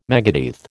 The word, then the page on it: megadeath: American English